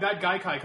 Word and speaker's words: Gaikai: I don't know how to pronounce it, but it's G-A-I-K-I. G-A-I-K-A-I. – I think that's right, yeah.